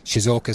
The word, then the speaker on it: Shizuoka: We're at Shizuoka Stadium.